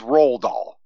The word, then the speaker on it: Roald Dahl: It's just Roald Dahl.